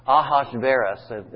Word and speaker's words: Ahasuerus: But probably the easiest way, there's no way to be certain what the pronunciation is, but most scholars pronounce this Ahasuerus,